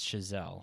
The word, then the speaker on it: Chazelle: I looked up the pronunciation on some French website. It's Chazelle, hopefully.